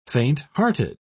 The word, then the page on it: faint-hearted: British English